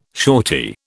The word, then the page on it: shortie: British English